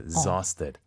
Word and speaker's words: exhausted: 발음이 중요한 게 z-z-z, exhausted. – 이그저 아니고 exa가 되는군요.